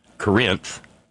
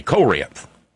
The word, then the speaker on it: Corinth: She'd been sent to Corinth, and if you want to say it correctly like they do down there in Alcorn County, Mississippi, you say Corinth, Corinth. It's kind of actually a double stress syllable.